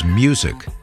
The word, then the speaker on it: MUSQ: That was David Schulhof, the founder and CEO of MUSQ, which we can pronounce as Music LLC, a new music ETF.